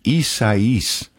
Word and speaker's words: Isaias: That's Hurricane Isaias. That's how you pronounce it. Looks like Isaias or something like that.